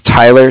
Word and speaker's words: Tyler: My name is Tyler, T-Y-L-E-R, mm-hmm. – And I'm calling with Cardiff. It's spelled C-A-R-D-I-F-F, as in Frank, Frank.